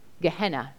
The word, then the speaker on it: Gehenna: We can pronounce this Greek word, Gehenna, and it means Valley of Hinnom.